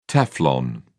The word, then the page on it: teflon: British English